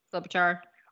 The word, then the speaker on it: Klobuchar: It's basically saying if Christine Gillibrand and Klobuchar, is that how you say it? Klobuchar? – Yes, Klobuchar.